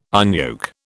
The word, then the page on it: unyoke: British English